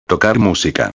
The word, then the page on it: tocar musica: espanhol